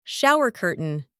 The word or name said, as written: shower curtain